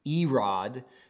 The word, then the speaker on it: Irad: So, Irad, A-I-R-A-D, is pronounced Erod in the Hebrew, and then the Mehujael, we would say Mehujael, but the J is silent.